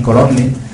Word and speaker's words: Colonne: lo digo tal y como se escribe con dos N, Colonne, en los que precisamente en documentos como el Follaggio o el Possessionum, pues sí tienen un origen ciertamente genovés.